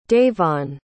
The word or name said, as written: davon